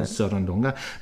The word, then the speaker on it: Sarandonga: Wir fangen an mit dem Titel, den Sie sich gewünscht haben aus dem Buena Vista Social Club Sarandonga, spricht man das so? Sarandonga. – Sarandonga, mit dem Solisten Compay Segundo.